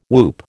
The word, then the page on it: whoop: American English